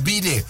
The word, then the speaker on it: bidet: It's bidet. Somebody saying it's French. Right. It's pronounced bidet.